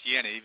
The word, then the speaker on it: Jenny: Jenny had a beautiful, and by the way, Hans Jenny is pronounced Jenny because he was Swiss. The J is pronounced as a Y.